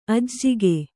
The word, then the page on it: ಅಜ್ಜುಗ: kannada